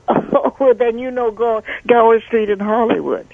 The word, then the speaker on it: Gower: G-O-W-E-R. – Gower. – Spell it for me one more time. – G-O-W-E-R.